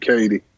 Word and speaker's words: Katy: And so they call me Big Cady. – Okay, C-A-D-Y. – No, K-A. – K-A-D-Y. Okay. Oh, it is spelled Katie, K-A-T-Y.